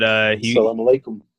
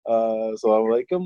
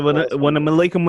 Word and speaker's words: Assalamu alaikum: Assalamu alaikum, alaikum salam. Yeah, I remember. – He butchered it. – Yeah, he said, when I'm alaikum, when I'm salam.